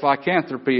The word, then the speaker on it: lycanthropy: It's called lycanthropy. And it's spelled L-Y-C-A-N-T-H-R-O-P-Y.